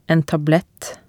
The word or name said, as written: en tablett